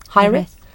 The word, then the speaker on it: hiraeth: And the title that she eventually um, ended up with is a Welsh word, which is, I think this is how you pronounce it. It's uh, hi, hi, hiraeth, hiraeth, hiraeth, yeah, hiraeth.